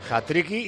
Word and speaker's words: hat-trick: Pero se ve ahí en esa acción precisamente hat-tricky y Endricky, que los brasileños a veces pronuncian así, el sonido K al final de la palabra.